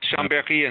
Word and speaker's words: Chambéry: You open the catalog, the preface of the catalog, with a story about visiting the French town of, and I'm going to botch the pronunciation here, Chambéry? – Uh, that's Chambéry and Saint, uh, Saint-Jean-Maurienne, yes. – I was close.